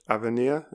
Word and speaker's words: avenir: Reading your work, I came across a word which I've not heard before, a French word, avenir. Is that correct pronunciation? – Yeah.